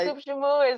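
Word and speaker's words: soup joumou: Yeah, soup jumu is the national dish of Haiti.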